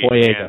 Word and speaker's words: Boyega: Boyega.